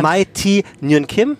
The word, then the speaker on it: Mai Thi Nguyen Kim: Dr. Mai Thi Nguyen Kim.